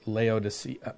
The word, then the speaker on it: Laodicea: Laodicea.